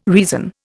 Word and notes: reason: Indian

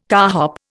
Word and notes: carhop: Indian

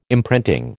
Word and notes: imprinting: American English

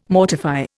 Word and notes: mortify: Indian

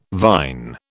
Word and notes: vine: British English

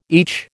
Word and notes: each: American English

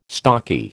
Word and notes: stocky: American English

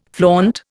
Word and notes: flaunt: Indian

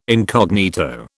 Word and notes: incognito: British English

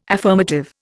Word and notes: affirmative: Indian

